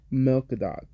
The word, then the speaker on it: Malcadox: Malcadox.